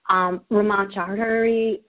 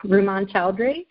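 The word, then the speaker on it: Rumman Chowdhury: Ramon Chaudhary, who is, and I just said her name wrong, Ramon Chaudhary.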